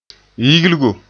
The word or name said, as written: yigligo